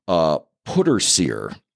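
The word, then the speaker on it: pwdre ser: And one other just quick thing before we get into the movie, I found out about this thing called putter sear, which is spelled P-W-D-R-E space S-E-R, Welsh, if you're wondering, okay.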